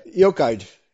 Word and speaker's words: Eochaid: I cannot pronounce it. – Really. Eochaid in Irish. Eochaid, yeah. Okay.